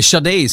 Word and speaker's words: Sade: Det var Sadie Nadé, eller Sade ska jag väl säga att hon heter, eftersom det är så man uttalar det.